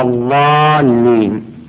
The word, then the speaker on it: الضالين: أيضا الضاد بعض الناس يحولها إلى الضاء غير المغضوب عليهم ولا الضالين، هذه غلط. بعض الناس يجيبها دال مرة غير المغضوب عليهم ولا الضالين، هذه غلط.